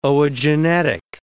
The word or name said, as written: oogenetic